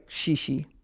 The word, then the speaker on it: xixi: It is the same thing in Brazil, in Portugal, and other Portuguese-speaking countries, and it's XIXI with accent on the final I. So a lot of people here think that shishi is Japanese.